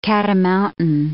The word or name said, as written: cat-a-mountain